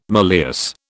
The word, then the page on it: malleus: British English